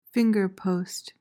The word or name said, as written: fingerpost